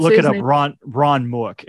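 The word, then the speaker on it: Mueck: The guy that plays Ludo, Ron Mook, is now this really famous, like hyper realist sculptor.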